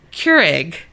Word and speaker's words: Keurig: he said, it's Keurig. There's no egg in it.